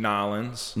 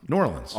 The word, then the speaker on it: New Orleans: All right, so he's from Nolens. – No, that's not how you say it. No. New Orleans.